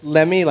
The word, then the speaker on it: Lemme: I don't know how to pronounce it, but it's Lemmy, like L-E-M-M-E.